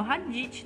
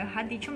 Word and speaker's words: radicchio: A salada americana, a gente tem a salada comum, a gente tem o radicchio, radicchio ele não é o radite, tá? Eu não tô falando errado. Ele é radicchio mesmo.